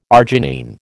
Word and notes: arginine: American English

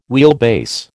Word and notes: wheelbase: American English

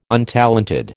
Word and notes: untalented: American English

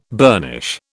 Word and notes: burnish: British English

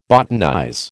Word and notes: botanize: American English

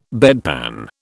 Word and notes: bedpan: British English

